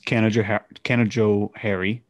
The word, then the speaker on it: Canajoharie: I feel like it'd be better if it sounded like English Canajoharie with a long A instead of a E. – Maybe it is. Oh, no, it definitely is hair. – It's hair. It's they emphasize hair. The hair.